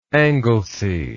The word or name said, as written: Anglesey